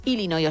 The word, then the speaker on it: Illinois: Pero es Illinois, o sea, si tú lo pronuncias bien y tienes buena dicción, es Illinois.